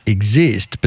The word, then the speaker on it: IIXIIST: And now you are, it's pronounced exist, but spelt IIXIIST.